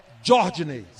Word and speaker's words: Jordinei: Como se fosse J-J-O. – Substituição no Botafogo. – R-D-I-N. E-I. Jordinei. – É porque tem um D, né, que inicia, que é mudo, né? E tem um H depois do J, né? – É, D-J-H. Começa como se fosse com D.